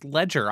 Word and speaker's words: Legere: It's actually pronounced Ledger. I had to look this up. – Wait, really? – Yeah, really. Huh. Ledger, like a book.